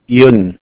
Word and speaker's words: Yun: Yun, Yoon, okay. – Y-U-N.